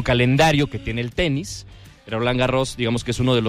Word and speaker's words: Roland Garros: vamos a hablar del Roland Garros, es la pronunciación en francés, para los que no entendieron.